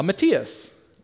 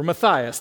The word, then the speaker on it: Matthias: Usually I feel like a Matthias or Matthias, depending on how you want to pronounce his name.